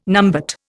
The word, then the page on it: numbat: Indian